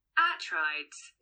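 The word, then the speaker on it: Atreides: So this is his last name pronounced in English. – Atrides. – And this is Greek pronunciation. – Atrides.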